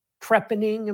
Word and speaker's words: trepanning: And so I think those books, by the way, have a lot of medicine in them, including a famous trepanning, am I saying that right? Where yep. Maturin puts, hammers out a shilling and puts it in somebody's head.